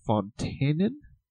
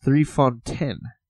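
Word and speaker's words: 3 Fonteinen: And I'm saying, okay, the spelling looks like it says Fontaine. But Evan educated me that it's just 3Fontaine. That's how they say it in Belgium.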